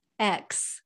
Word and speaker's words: Ekx: X. – X. – But it's spelled E-K-X.